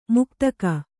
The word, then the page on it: ಮುಕ್ತಕ: muktaka